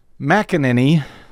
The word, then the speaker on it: McEnany: His Trump's press secretary, Kayleigh McEnany, I guess, still don't know how to say her name. – That's close enough. – She read the aptly named McEnany, I guess.